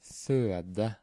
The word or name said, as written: føde